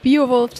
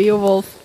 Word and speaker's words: Beowulf: Also es ist nicht der Biowolf, sondern der Beowulf.